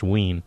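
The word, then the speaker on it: Wein: And interestingly enough, those two were directed by Daryl Ween, and I think I was calling him Wayne on the show, so beg your pardon, but I think it's pronounced Ween.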